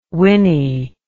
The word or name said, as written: winny